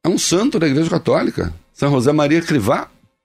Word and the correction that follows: Escrivá: Acha a foto dele aí. É, São José Maria Escrivá, se escreve.